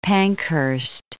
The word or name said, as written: Pankhurst